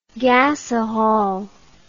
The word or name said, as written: gasahol